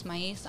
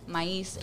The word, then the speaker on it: Maiz: Okay, so Victoria, you can find me at Anais Maiz. Anais is my middle name. Maiz, you got to spell that. – Is your last name? – So, listen. Okay. Anais Maiz, A-N-N-A-I-S, Maiz, M-A-I-Z.